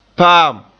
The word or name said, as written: paam